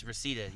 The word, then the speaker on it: Reseda: Yes, Reseda.